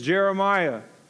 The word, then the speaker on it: Jeremiah: Friends, let me tell you, my name is Jeremiah. J-E-R-E-M-I-A-H, like the Jeremiah in the Bible. Not J-E-R-I, not J-E-R, not J-E-R-A. It is J-E-R-E-M-I-A-H.